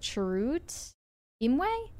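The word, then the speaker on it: Chirrut Îmwe: Is it Chirrut Imwe? Is that it? – Yeah.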